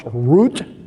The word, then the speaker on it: Ruth: And one of them chose to stay, and the other one, Ruth, whose name in Hebrew is pronounced Root, because there's no TH sound in Hebrew, Root decided that she would go with her mother, Naomi.